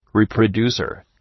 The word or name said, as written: reproducer